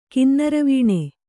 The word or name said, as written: ಕಿನ್ನರವೀಣೆ